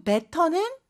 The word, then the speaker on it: better: 그리고 better는 better. 이렇게 T 발음이 부드럽게 변하죠.